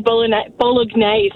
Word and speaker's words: Bolognese: Bolognese. – And they had never been corrected by anyone before, and all the other teams were like, what are you doing? Like, the G is silent.